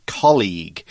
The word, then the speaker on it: colleague: Uh, now, a colleague, make sure, first of all, this is a hard G. It's a G sound. Mm -hmm. Colleague. It's not college, which is a school, which is a soft G. This is colleague. Mm -hmm. The, the U is a bit of a clue.